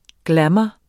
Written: glamour

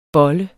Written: bollet

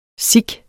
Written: sic

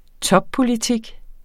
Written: toppolitik

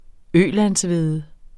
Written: ølandshvede

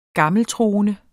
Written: gammeltroende

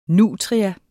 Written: nutria

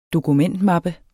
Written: dokumentmappe

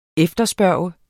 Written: efterspørge

